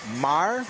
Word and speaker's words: Maher: Ça s'écrit M-A-H-E-R, mais ça se prononce Maher.